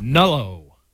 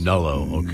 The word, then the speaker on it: Nullo: So what is the, I didn't get a chance to read the story, but the headline says the dangerous Nullo penis fantasy that most men's worst nightmare. Is people want to chop it off? – Nullo is how that's pronounced. – Nullo, okay.